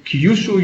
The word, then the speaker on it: Kyushu: all from Kyushu University. Did I pronounce that correctly? Yeah, that's correct.